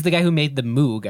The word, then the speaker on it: Moog: It's pronounced Moog. It's a synthesizer. – It is a synthesizer. – Oh, trust me. – Oh, yeah. Moog.